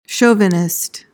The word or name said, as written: chauvinist